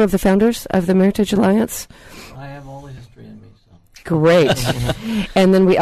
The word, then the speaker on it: meritage: And that word is, and I hope I'm saying it right, meritage. Rhymes with heritage, right? – Right.